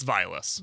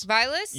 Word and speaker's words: Vilas: You guys were saying it funky. It's Vilas. – Vilas? – Yeah.